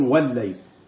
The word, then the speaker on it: والليل: ولا تقول إن الليل هي اللام مظهرة، لا، وال هي كده، ها، والليل والليل، لكن أنا بقول والليل، فهكذا أنطق بها مشددة.